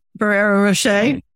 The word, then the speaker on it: Ferrero Rocher: Oh, my favorite chocolate is going to be the, was it Ferrero Rocher or how is it pronounced? – Ferrero Rocher. – Yes, Rocher. Yeah. There you go.